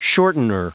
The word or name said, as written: shortener